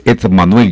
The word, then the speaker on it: Edmanuel: Sí, señor, ese es el significado de mi nombre, es Edmanuel. Yo me llamo Edmanuel, Dedo Manuel. Todo pegado, Ed Manuel. Ese es como se escribe.